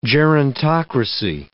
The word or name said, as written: gerontocracy